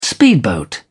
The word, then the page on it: speedboat: British English